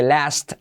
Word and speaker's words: last: Y mirad que last, la T no se pronuncia, como pone en la transcripción.